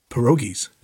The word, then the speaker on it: pierogies: Well, it's just like I've never had pierogies till I was out east. – Pierogies? – Is that how you say it? Am I saying it wrong? – No, that's right.